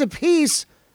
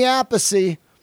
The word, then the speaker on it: Appice: And yes, it is a piece. I learned how to say it. If it's Vinny, it's Vinny Appice. And if it's Carmine, it's Carmine A Piece.